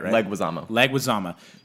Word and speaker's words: Leguizamo: John Leguizamo. – Leguizamo. – I said it right? – Leguizamo. – Dude, his... – Dead. D-E-D.